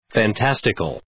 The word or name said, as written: fantastical